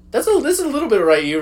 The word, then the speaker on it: Ryu: It's Ryu, not Ryu. – No, I keep going back. – Hello, it's Ryu. Actually, the actual Japanese pronounce is Ru.